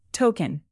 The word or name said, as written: token